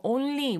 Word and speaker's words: only: 너무 네이셜 사운드가 콧바람이 너무 들어갔다고 그냥 자연스럽게 힘 빼고 only, only 이렇게 하라는 거예요.